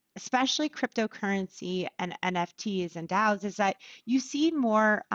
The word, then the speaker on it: DAOs: Yeah, DAOs.